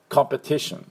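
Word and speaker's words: competition: で、第二、ちょっと弱めですけど、ちょっとあるのはこの最初のコのところで、コンペティションっていうふうに発音します。 T の方が強いです。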